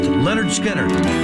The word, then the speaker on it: Lynyrd Skynyrd: Let's give you the anthem right now and get things started right on the anniversary release of the debut album by Lynyrd Skynyrd, pronounced Lynyrd Skynyrd, on the station with the best classic rock ever made here at MGK.